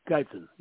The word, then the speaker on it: Guyton: How you pronounce it, Gayton, offensive tackle, who is a monster. He's like 6'8", from Oklahoma. – Where's he from? Oklahoma? – Oklahoma. – Yeah. G-U-Y-T-O-N, yeah. – Gayton, yeah.